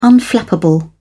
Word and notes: unflappable: British English

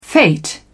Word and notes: fate: British English

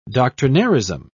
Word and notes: doctrinairism: British English

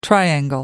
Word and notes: triangle: American English

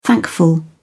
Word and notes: thankful: British English

